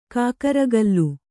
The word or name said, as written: ಕುಕ್ಕರಗಾಲು